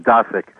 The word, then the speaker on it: Dosick: And just as long as we're dealing with names, mine is a difficult name to pronounce. It's actually pronounced Dosick.